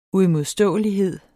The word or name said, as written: uimodståelighed